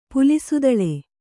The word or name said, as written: ಪುಲಿಸುದಳೆ